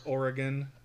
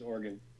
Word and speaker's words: Oregon: Yeah, we um, have to be right, and in or- Oregon. – Uh, yeah. Say it correctly. It's Oregon.